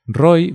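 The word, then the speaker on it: Roi: En mi caso, a ver, más o menos, la gente lo sabe, pero Roy, mi nombre se escribe con I latina, la I del punto. No se escribe con Y porque con Y se escribe Roy, pero el nombre anglosajón, el nombre inglés.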